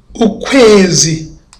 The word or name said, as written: ukwezi